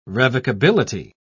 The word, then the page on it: revocability: British English